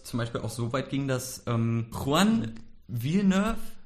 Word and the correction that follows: Villanueva: ich weiß nicht. – Villanueva. Villanueva. – Ich glaube, dass der Nachname so ausgesprochen wird.